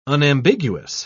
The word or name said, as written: unambiguous